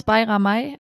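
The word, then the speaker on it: Bajramaj: Alushi, ehemals Bayramay. – Super, ja. Gut ausgesprochen.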